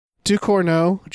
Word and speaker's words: Ducournau: Ducournau? – I'm sure I'm saying that wrong. – Yeah. Ducournau. Julia Ducournau. That sounds fine.